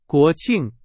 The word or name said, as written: 国庆